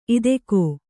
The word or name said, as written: ಇದಕೋ